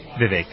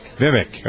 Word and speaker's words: Vivek: Am I pronouncing it? It's Vivek? – Vivek. – Vivek, okay. You have founded Doctors for America.